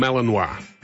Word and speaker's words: Malinois: I think the way to my eye, that would be pronounced a Belgian Malinois. – Malinois, if it's Belgian. Yeah. M-A-L-I-N-O-I-S. – Or is it a Malinois? – Yeah, you go with Malinois. All right, I'm going with Malinois.